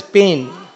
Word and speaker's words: Pyne: Even the name P-Y-N-E, but it actually, you pronounce it as Payne.